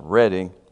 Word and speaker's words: Reading: And I thought, well, I said, sir, excuse me, it's pronounced Reading.